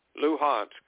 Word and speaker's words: Luhansk: Moscow, will not only recognize Donbass, Moscow will not agree to the recognition of the independence of Donetsk and Luhansk. Or how do they pronounce that? – Luhansk.